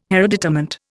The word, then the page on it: hereditament: Indian